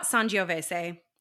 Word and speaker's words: Sangiovese: While there was not a lot about this particular wine, there's quite a bit to say about Sangiovese. And it's pronounced that way. It's not Sangiovese.